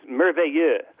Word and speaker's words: Merveilleux: You just butchered her name. Is Merveilleux.